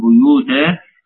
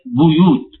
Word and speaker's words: بيوت: اتكلمنا على بيوتا المرة اللي فاتت، وقلنا إن عندنا بعض الكلمات اللحص فيها بيخالف لغة أهل مصر وبيجيب نطق مختلف، وهي مثلا منها كلمة بيوت بضم الباء. احنا عندنا بنقول بيوت أو نقول بيتي بيتك، ولكن هنا حفص يقول بيوت بيبدأ بها الباء مضمومة. نعم.